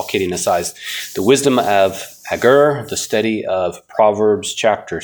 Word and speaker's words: Agur: I have heard his name pronounced. It's funny. I'm just a little bit lighthearted here. I think I've got six different pronunciations that I found for his name. Yeah. Agur seems to be the most common, so I'll stick with that.